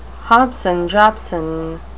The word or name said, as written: hobson-jobson